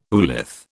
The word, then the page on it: oolith: British English